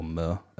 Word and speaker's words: MØ: And quickly on her name, Mo, or Mer, as it's pronounced in Danish, is just obviously her initials, like her middle name's Marie.